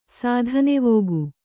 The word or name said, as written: ಸೋಧನೆವೋಗು